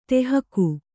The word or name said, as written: ಟೆಹಕು